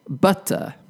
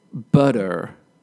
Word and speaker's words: butter: For pronunciation, American English is often different when it comes to the T sound or the T sound in the middle of words. For example, I, as a British person, would say butter, pronouncing the T sound, the T, in the middle of the word.